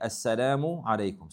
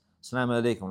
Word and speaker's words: assalamu alaykum: Some people, they just say it fast and they eat letters. Salam alaykum, like this, salam alaykum, whatever the case may be. No, there's a proper way to say it.